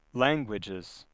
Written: languages

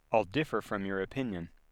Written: differ

